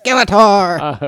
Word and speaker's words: Skeletor: You're saying it wrong. It's Skeletor.